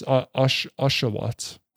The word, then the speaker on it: Oshawott: I think it's Ocelot. – God damn it. It's Oshawott.